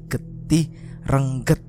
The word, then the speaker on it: getih rengget: Jadi sebenarnya pelafalannya atau pronunciationnya itu bukan rengget kata Mas Wira, tapi yang betul adalah getih rengget. Nah, jadi yang betul itu adalah getih rengget guys, bukan getih rengget.